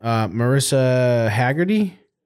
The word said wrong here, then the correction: Mariska Hargitay: Mariska Hargitay.